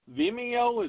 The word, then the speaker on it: Vimeo: They're getting hard to operate with, but we're on Vimeo. Is that how you pronounce it? V-I-M-E-O? – Yep.